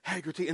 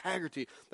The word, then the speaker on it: Hagerty: And no one can pronounce it right. I would try to tell them it's Hagerty. They say, Hagerty. You know, around here, Hagerty. Like, can't nobody pronounce Hagerty. You know, it's just, it's Hagerty.